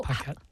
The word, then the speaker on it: Paquette: Yeah, basically, it's a French name, but I'm American, so I pronounce it like an American. Paquette.